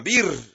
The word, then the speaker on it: Ber: De ahí vinieron a Bir, B-E-R. Este es el pozo del cual Jehová dijo a Moisés: reúne al pueblo y les daré agua. La pronunciación de este, de ese lugar, ese pozo, es B-E-R.